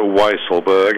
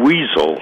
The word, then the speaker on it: Weisselberg: It is a Weisselberg, as in wise. It's not the more tempting weasel, which is, I know, has been occurring to many people's lips as they consider how to pronounce his name. Weasel, as in that ferret-like animal, with an unfair reputation, I suppose I should say, for deceptiveness. That is not how you say his name.